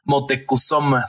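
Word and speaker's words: Motecuhzoma: Algunos eh, historiadores mm, anotan que el nombre de Moctezuma era en realidad, o se pronuncia en realidad, Motecuzoma o Coyotzin. Nada más que los españoles eh, no lo podían pronunciar o les costaba trabajo y lo terminan cambiando a Moctezuma.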